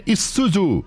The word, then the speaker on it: Isuzu: É, Diego, você escreve em Zuzu, mas a pronúncia é Isuzu.